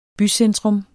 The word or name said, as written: bycentrum